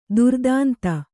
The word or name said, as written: ದುರ್ದಾಂತ